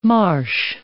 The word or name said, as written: marsh